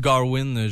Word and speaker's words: Gawain: Ok, c'est comme ça en anglais qu'il prononce. Garvin, je ne sais pas en français. – Gauvin en français. – Gauvin en français. Sir Gawain, je l'écoute en anglais.